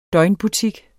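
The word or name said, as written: DØGNET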